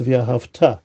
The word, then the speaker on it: Ve'ahavta: So once again, Ahavta is past, but Ve'ahavta is future, okay, and it should be pronounced Ve'ahavta, where the emphasis is on the future syllables, which emphasizes the idea that this the overall meaning is talking about in the future.